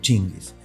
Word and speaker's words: Tsingy: T-S-I-N-G-Y. Pero se pronuncia así como les estoy diciendo, chinguis.